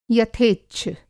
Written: यथेच्छ

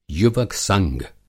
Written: युवक संघ

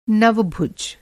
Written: नवभुज

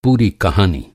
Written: पूरा

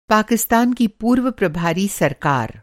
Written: प्रभारी

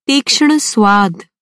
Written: तीक्ष्ण

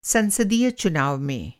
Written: संसदीय

